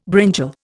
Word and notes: brinjal: Indian

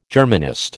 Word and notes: Germanist: American English

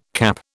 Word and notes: CAP: British English

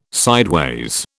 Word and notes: sideways: British English

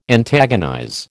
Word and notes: antagonize: American English

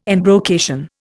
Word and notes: embrocation: Indian

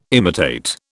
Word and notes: imitate: British English

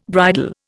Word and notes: bridle: Indian

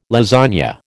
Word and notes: lasagne: American English

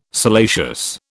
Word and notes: salacious: British English

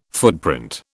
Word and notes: footprint: British English

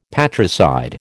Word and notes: patricide: American English

